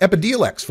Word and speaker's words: Epidiolex: And we've got Epidiolex, yeah, that's the way it's pronounced, Epidiolex from GW, their version of Alepsia, this high CBD extract for use in certain forms of epilepsy.